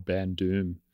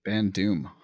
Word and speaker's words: Bandoum: Like there's a guy who does this really well called Ban Doom, actually. – How do you spell that? – B-A-N-D-O-U-M. – Ban Doom.